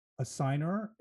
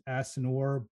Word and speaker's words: assignor: If you are a run-of-the-mill American, you pronounce it assignor. If you are a pompous muckety-muck like me, you pronounce it assignor. Both are acceptable.